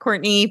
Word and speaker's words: Courtenay: Well, I am Courtney Turner of the Courtenay Turner podcast. I spell my name like Courtenay. So Courtenay. It is pronounced Courtney, but it's spelled Courtenay Turner.